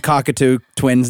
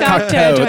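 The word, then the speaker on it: Cocteau Twins: I was going to say them and Cockatoo twins. They share the same background. How do you say it? Yeah, Cocktoe. There you go.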